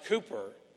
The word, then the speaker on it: Cowper: Looks like Cowper, but it's pronounced Cooper.